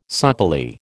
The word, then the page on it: supplely: American English